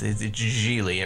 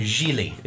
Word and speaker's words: Gigli: Much like Ben Affleck, I'm gonna have to insist that you do the soft G. It's GIGLI. It rhymes with really. – GIGLI.